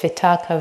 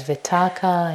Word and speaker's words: vitakka: So for those who might be interested in the Pali words, vitaka, V-I-T-A-K-K-A is how it's spelled. Vichara is V-I-C-A-R-A.